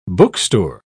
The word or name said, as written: bookstore